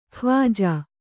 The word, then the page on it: ಖ್ವಾಜಾ: khvājā